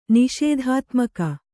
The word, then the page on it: ನಿಷೇಧಾತ್ಮಕ: Kannada